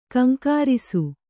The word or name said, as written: ಘೀಂಕರಿಸು